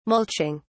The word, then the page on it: mulching: British English